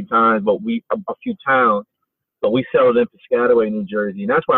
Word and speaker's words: Piscataway: That was in Piscataway, New Jersey. – Yes. Yep. I went to high school and middle school in Piscataway, New Jersey.